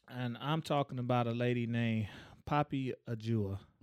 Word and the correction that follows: Ajudha: Mm. – Ajudha, excuse me. – How you spell that? – It is P O P P Y A J U D H A.